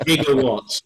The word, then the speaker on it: gigawatts: I'm just still tricky by the fact that the gigawatts, it's a hard G.